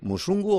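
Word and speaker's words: Mzungu: Yo no sé si lo he pronunciado bien. Musungu, Operación Congo, sí. ¿Está bien dicho? – Perfecto, perfecto.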